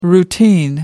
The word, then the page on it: routine: American English